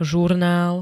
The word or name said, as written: zurnal